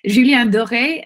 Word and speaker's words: Julien Doré: Do you want me to say? – Yeah, I do. – Fever. Julien Doré and Fièvre.